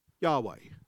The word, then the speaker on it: Yahweh: No one really knows how the Hebrew word is pronounced. We think it's pronounced Yahweh.